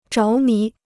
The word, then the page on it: 着迷: zháo mí